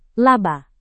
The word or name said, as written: laba